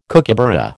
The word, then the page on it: kookaburra: American English